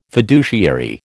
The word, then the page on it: fiduciary: American English